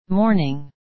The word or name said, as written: morning